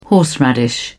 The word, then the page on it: horseradish: British English